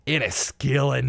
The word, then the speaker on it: Enniskillen: A bomb goes off in a Remembrance Day, and Enniskillen, sorry, I've got to say it right, Enniskillen, Northern Ireland. That was Scottish. I didn't mean to do that.